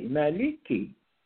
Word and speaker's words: Maliki: It is a long A, like and this is L, and this is K, Maliki.